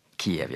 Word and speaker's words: Kyiv: Og det betyr at det er Ukraina-krisa, og det fører oss til Kiev. Ja, hovedstaden der heter Kiev, og det er det vi er vane med å si på norsk. Det er den russiske uttalen for den ukrainske, og dette er jo, nå er jo Ukraina et selvstendig land.